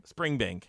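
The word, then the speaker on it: Springbank: Did I say it wrong? – I don't know. We have this. It's right over there, but in Campbelltown, yes. Spring Bank.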